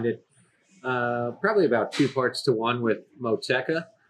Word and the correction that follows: Motueka: Motueka, I guess, if you want to pronounce it correctly.